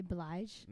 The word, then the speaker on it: Blige: Blige.